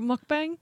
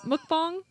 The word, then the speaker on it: mukbang: We could have a group mukbang. – Mukbang. – Mukbang. Mukbang. Mukbang. I don't know. I know it's spelled bang, but I think it's pronounced bong.